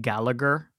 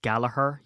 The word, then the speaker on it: Gallagher: Anyone who likes the band Oasis will know Noel and Liam Gallagher, but it's not, it's Gallagher. You don't pronounce the G.